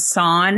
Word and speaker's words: SAAN: Did you pronounce it SAAN? – Yeah, we call it SAAN.